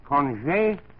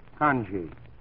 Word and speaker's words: conge: Now, for $50 bill, your last knee action word is pronounced conge, or the anglicized conge, spelled C-O-N-G-E.